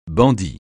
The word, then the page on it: bandit: francais